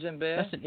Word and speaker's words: djembe: Now, on the liner notes here, it says that another instrument, the djembe, is also, am I pronouncing that right, djembe? – Yes, it's djembe.